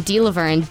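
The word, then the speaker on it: Delavergne: Sarah, S-A-R-A. Last name, Delaverne, D-E-L-A. V as in Victor, E-R-G-N-E. – Three capitals, folks, and a silent G.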